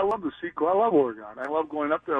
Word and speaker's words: Oregon: You got to say Oregon, like, you know, a piano.